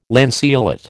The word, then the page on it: lanceolate: American English